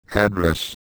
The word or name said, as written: address